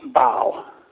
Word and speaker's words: Baal: And I guess is it pronounced Baal or Baal? – Baal. – Baal, okay. – Baal, yeah.